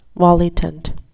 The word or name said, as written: volitant